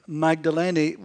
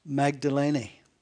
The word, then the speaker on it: Magdalene: And by the way, it is pronounced Magdalene. We always say Magdalene, but it is Magdalene, denoting the area where she came from, which would be just south of Capernaum and the western shores of the Sea of Galilee.